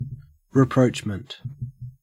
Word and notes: rapprochement: Australian English

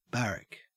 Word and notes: barrack: Australian English

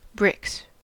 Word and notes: bricks: American English

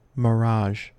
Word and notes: mirage: American English

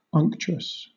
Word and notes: unctuous: British English

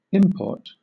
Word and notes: import: British English

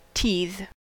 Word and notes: teethe: American English

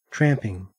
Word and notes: tramping: Australian English

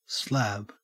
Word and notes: slab: Australian English

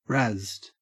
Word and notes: razzed: Australian English

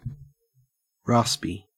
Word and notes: raspy: Australian English

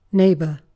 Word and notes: neighbour: British English

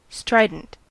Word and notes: strident: American English

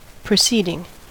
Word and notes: proceeding: American English

